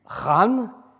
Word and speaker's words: Khan: lance un grand raid contre le camp du Khan, je prononce Khan pour ne pas dire le camp du camp, n'est-ce pas Le camp du Khan Kargluk un autre peuple turc, à Talas, la ville de Talas, qui correspond au Zamboul d'aujourd'hui.